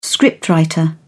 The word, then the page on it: scriptwriter: British English